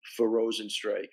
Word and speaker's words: Rozenstruik: Just it had to be a frustrating fight, you know, for Rosenstrike. I mean, am I pronouncing his name right? – You're pronouncing his name right.